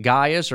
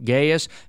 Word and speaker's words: Gaius: And so there's a good man named Gaius, or we could pronounce his name Gaius, who is taking the leadership of this church, but maybe he is layman leadership.